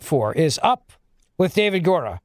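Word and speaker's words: Gura: David Gora, is that his name? Am I saying that right, David? – Gora. Up with David Gora.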